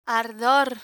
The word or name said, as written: ardor